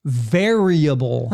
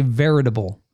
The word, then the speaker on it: variable: And it's spelled V-A-R-I-A-B-L-E. – Yeah. – But you say veritable. You add a T. I don't know where the T comes from.